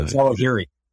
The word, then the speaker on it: Zawahiri: Yeah, Zawahiri.